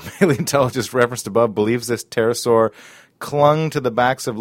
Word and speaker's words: pterosaur: Isn't the P not technically pronounced? – That's, like, yes, pterosaur? True. – Okay, you're just doing it to be funny. Okay, so people aren't confused. – Well, and the listener will now know that it starts with a silent P instead of a T.